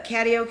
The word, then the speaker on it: Cadieux: It's C-A-D-I-E-U-X. – Cadieux. – Cadieux Cafe. – Cadieux or Cadieux. Maybe. The U-X is an O. – I imagine it's a Belgian word. – Okay. – The Cadieux Cafe in Detroit is proud to be the only home of the feather bowling in the U.S.